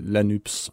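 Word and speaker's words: Nupes: men han siger La Nyps, så La jeg nyps. udtaler S'et.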